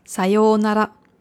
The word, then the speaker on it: Sayonara: Sayonara. Vorsicht bei der Aussprache, also nicht Sayonara, nicht auf dem Nara, sondern auf dem Sayo.